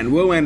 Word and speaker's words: WoAN: News, big news, Woan is on the way. – Woan, it's spelled like moan, M-O-A-N, except with a W in front. – Yes, W-A-N.